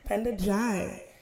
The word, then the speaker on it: Ndiaye: And today, we're really happy to be joined by Penda Ndiaye. Am I pronouncing your last name correctly? – It's pronounced Jai, like it rhymes with pie. Jai. – Penda Jai.